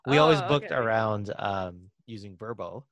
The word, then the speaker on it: VRBO: VRBO is actually pronounced Verbo.